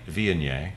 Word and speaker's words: Viognier: Viognier.